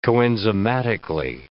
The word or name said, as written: coenzymatically